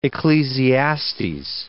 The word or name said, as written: Ecclesiastes